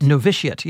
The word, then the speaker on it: Novitiate: Novitiate? – Novitiate, yes.